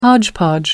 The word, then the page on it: hodgepodge: American English